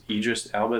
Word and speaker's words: Idris Elba: The last one, Covenant, it was the first movie I really got to know, which is Idris Elba, is that how you say it? – Yeah, yeah.